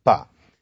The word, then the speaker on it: pas: Classic example of this is the French negative marker pas, spelled P-A-S and pronounced pas.